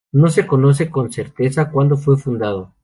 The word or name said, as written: fundado